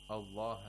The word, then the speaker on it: Allah: If you look, for example, in the Uthman Taha Codex, you'll see that because everyone knows that Allah has to be pronounced with, has to be pronounced like a long vowel with an Aleph with Mad.